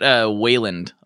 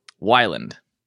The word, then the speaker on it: Wyland: I get Wayland a lot. I'm like, Blake Wayland. Like, there's no A there, but hey, you know? – Oh, wait. How do you say it? – Wyland.